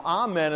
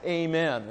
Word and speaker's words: amen: Now, amen is a proper way to pronounce it. But how many Baptists say amen in the middle of a sermon? I look at that and think, well, that's the Presbyterians. So I say amen.